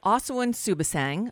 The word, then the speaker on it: Suebsaeng: On the line with us now is Asawin Suebsaeng.